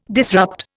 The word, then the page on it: disrupt: Indian